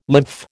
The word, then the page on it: lymph: American English